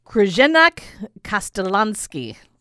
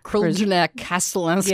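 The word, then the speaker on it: Crljenak Kaštelanski: Krzyżanek Castellanski. – Yeah, C-R-L-J-E-N-A-K, for anybody taking notes at home and wants to look it up, K-A-S-T-E-L-A-N-S-K-I.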